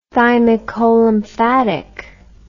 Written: thymicolymphatic

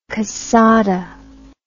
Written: cassada

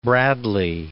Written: Bradley

